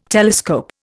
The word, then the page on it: telescope: Indian